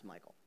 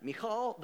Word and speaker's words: Michal: Let's get to this response of Michal, wife of David, not pronounced Michael, all right? Because that's confusing. All right, Michal, the wife of David, resents her husband.